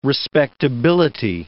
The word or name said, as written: respectability